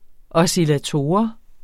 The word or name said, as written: oscillator